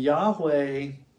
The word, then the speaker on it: Yahweh: So when you see the term Yahweh, in the Old Testament, it's L-O-R-D in all capitals. And Yahweh, the way it's pronounced, sounds very similar to what God told Moses at the burning bush in Exodus 3:14.